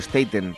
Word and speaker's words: Staten: Staten Staten, para aquellos puristas del, inglés y la pronunciación.